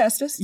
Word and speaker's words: Estes: Did I butcher your last name again? Because I feel like I always do and I feel bad. – No, you said it right. You said Estes.